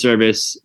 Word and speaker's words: Servis: So our last name is Service, S-E-R-V-I-S is how you spell it.